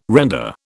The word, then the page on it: render: British English